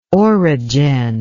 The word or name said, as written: orogen